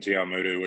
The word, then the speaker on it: Te Awamutu: Um, uh, uh, online it, it, tells me that you were born in I, I, might butcher this, but I think it's Te Awamutu on the North Island. am I right about that? – Yeah, so I was born in Te Awamutu, which is 20 minutes well, it is in the Waikato, sorry.